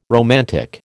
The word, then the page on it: romantic: American English